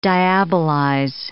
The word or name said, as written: diabolize